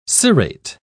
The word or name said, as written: cerate